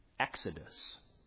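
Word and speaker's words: Exodus (ἔξοδος): Let me pronounce it for you in Greek. Exodus.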